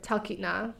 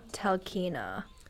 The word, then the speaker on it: Talkeetna: How do you say it? – Talkeetna. – Talkeetna. – Talkeetna. – Talkeetna. – Yeah. Yeah, there's, like, that extra T before the N.